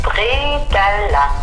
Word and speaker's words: Bredele: Bredele, ça se prononce bredala.